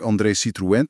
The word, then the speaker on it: André Citroën: Het 15e heeft heel veel squares en dat grote, moderne park André Citroën perfect French pronunciation.